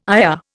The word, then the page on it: ire: Indian